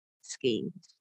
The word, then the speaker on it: Skene: If it's fluid from the, I probably should have Googled how to pronounce this, skeins, I'm going to assume, skeins. – That's what it looks like, yeah. – So it's spelled S-K-E-N-E, skeins, skeins gland.